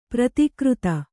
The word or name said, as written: ಪ್ರತಿಕೃತ